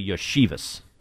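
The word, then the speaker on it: Iosivas: Anyway, Fitzy says, we're mispronouncing the new top receiver's name for the Bengals. It is Andre Yoshivas.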